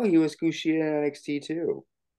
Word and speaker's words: Kushida: Tashida, is this his dude's name? I can't pronounce this fucker's Kushida.